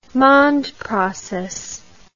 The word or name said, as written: mond process